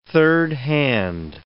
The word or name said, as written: thirdhand